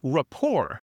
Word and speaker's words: rapport: So instead of rapport, it's spelled R-A-P-P-O-R-T. It looks like rapport, but we don't pronounce the ending.